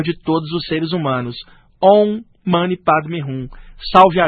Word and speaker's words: Om Mani Padme Hum: No Tibete, às vezes a pronúncia pode mudar um pouquinho, tipo Om Mani Padme Hum, ou então Om Mani Padme Hum. Já na China, os chineses, como não sabiam falar o sânscrito, adaptaram às vezes da seguinte maneira, Om Mani Padme Hum, né? Ou Om Mani Padme Hum. Então a pronúncia pode variar, se é chinesa, tibetana, mas o original vem do sânscrito, mas esse mantra é mais aplicado no budismo tibetano.